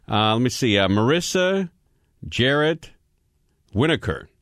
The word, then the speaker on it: Winokur: Winokur. – Winokur.